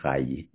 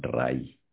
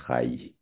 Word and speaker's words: Rayi: The surname is spelled R-A-Y-I. But it's pronounced as Chai, not Rai. Chai.